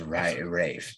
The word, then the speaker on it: Ralph: It's Ralph, you say. It's pronounced Rafe. It's Rafe. – With an F. – Yes, Rafe.